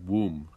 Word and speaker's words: womb: So it's pronounced like WOOM, and tomb is pronounced TOOM. But bomb is not. It doesn't follow that. – Tomb? – Tomb and womb, but bomb. – Maybe it's not a boom. – That's right, but it's how it's spelled. – No, but the other ones have a double M, right? – No. Womb is spelled W-O-M-B. Tomb is spelled T-O-M-B. And bomb is spelled B-O-M-B. – Okay. – So it doesn't follow the other ones.